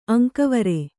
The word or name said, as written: ಅಂಕವೇರು